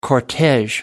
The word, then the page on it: cortege: American English